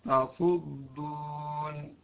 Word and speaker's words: تصدون: أيضا لو جاء بعدها صاد، تصدية أو تصلية أو تصدون، لا تنطقها طاء، لا تقول طاصود طاصود، لا خطأ، تاء وليست طاء. أيضا لو جاء بعدها ظاء تظلمون، لا تقول تظلمون، تاء وليست طاء، تظلمون.